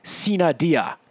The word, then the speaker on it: sine die: The Latin pronunciation is sine die.